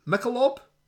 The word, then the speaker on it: Michelob: The gig was sponsored by, I don't even know how you pronounce that, Michelob? – Michelob. – What's Michelob? – It's an American beer. – Right, there we go.